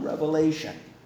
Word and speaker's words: Revelation: There's no S. It's only one revelation.